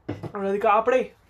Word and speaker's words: Radhika Apte: Radhika Apte.